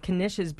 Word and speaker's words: Knish: Do people sometimes not understand and not pronounce the K in Knish? – Yes, that happens.